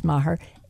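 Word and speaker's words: Meagher: E-I-L-E-E-N, @eileenmaher, one word, E-I-L-E-E-N, and Maher sounds like when it's spelled meager, but it's really pronounced Maher, M as in mother, E-A-G-H-E-R.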